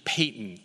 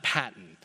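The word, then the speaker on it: Paton: It's actually Payton, not Patton.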